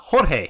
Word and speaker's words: Jorge: Jorge. – Oh, Jorge. – Jorge. And yeah, I was like, no. Yeah, so he's that, you know, that's how you'd pronounce George in like a Spanish dialect.